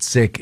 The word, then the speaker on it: Sikh: some pronounce it Sikh, some pronounce it Sick.